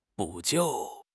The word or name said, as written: 补救